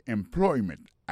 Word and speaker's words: employment: Alors, mot employment là, c'est employment. Accent tombé sous deuxième syllabe là.